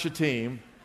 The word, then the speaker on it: Shittim: I know it looks like something else, but it's pronounced Shittim.